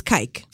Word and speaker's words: kijk: The Dutch word for look, to look, is kijk. I believe it's spelled K-I-J-K, kijk.